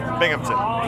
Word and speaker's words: Binghamton: Hey, this is Tim Nordwin from the band OK Go, and you're listening to WHRW Bimington. Is that right? – Binghamton. – Bimington. How do you say it? – Binghamton.